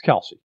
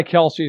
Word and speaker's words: Kelce: As someone who has a last name that also ends in an E that gets butchered constantly, listen, it's Kelce. Okay. He's gone by Kelce his entire career. I've heard him talk about himself, and I've heard the name come out of his mouth, Kelce, a million times over.